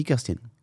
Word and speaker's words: Kirsten: Kirsten, c'est comment ça s'écrit et ça se dit Kirsten.